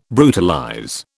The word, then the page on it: brutalize: British English